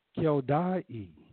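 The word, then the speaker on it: Heldai: It says the 12th captain for the 12th month was Heldah the Naphtophatite of Othniel, and in his course was 24,000. And who is this Heldah? And really, in Hebrew, it's not pronounced that way. In Hebrew, it's pronounced Keldai, and this Keldai or Heldah come from the Hebrew word Keldai, which means world, worldliness.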